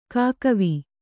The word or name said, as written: ಕಾಕಿವೆ